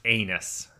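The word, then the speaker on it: Ennis: And Tom, I know you're typically the guy that corrects me on pronunciation, but the town is actually, it's pronounced anus.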